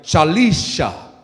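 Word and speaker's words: Shalishah: Also to Lisha, I'm going to say it right. Shalisha? Let me say it right. Shalisha.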